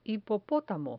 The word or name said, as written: hipopotamo